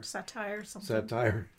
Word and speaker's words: satyr: Satire.